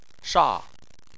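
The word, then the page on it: sha: tone 4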